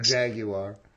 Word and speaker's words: Jaguar: Jaguar. Three syllables for those of you that call it a Jaguar.